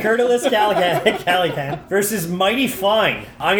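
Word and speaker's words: Kurtulus: It's K-U-R-T-U-L-U-S. – It's Curtulus.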